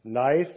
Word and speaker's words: knife: Knife, the K is silent.